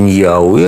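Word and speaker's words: Ñahui: Ну, произносится Ньяуи, а пишется Нахуи.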